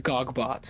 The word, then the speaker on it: Gogbot: organizer, and he says Gogbot, so you Okay. – can just pronounce it like that.